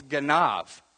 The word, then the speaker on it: ganav: It's ganav. Say ganav. Okay, it's spelled G-A-N-A-B, if you want to spell it out, but it's ganav.